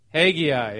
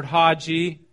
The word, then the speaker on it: Haggai: Last week we were in the book of, everybody know how to pronounce it? Haggai, yep. He goes Haggai, and I've heard Haji. What was yours, Chris? Was yours Haji? Yeah, so we've heard a lot of different pronunciations. I've always heard it pronounced Haggai or Haggai.